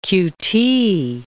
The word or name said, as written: q.t.